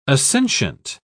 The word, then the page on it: assentient: British English